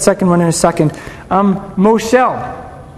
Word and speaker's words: Moshel: The Hebrew word for ruler is Moshel. Everybody say Moshel. I would spell that M-O-S-H-E-L, Moshel.